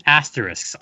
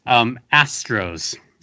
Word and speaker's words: Astros: and cheer against the Houston Asterisks. I'm sorry, the Houston Astros. Emphasis on the first syllable.